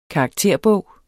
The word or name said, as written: karakterbog